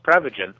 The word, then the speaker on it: Prevagen: So remind us, what exactly is pre-vegan? – Well, technically it's pronounced Prevagen,